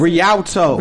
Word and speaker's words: Rialto: I can't pronounce Rialto.